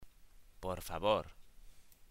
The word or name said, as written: por favor